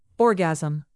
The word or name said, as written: orgasm